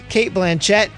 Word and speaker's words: Blanchett: I would say that my 8s would be judged on Kate Blanchett is high caliber actress. super sexy. – Why are your syllables so weird? Cate Blanchett, an actress. – Cate Blanchett. – Cate Blanchett, that's her name.